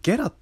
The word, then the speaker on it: GERA: いってとこにフォーカスした音声のアプリを発見したのでそれについてちょっとお話ししていきますで皆さんあのゲラというアプリをご存知でしょうかあのローマ字で GERA でゲラと読む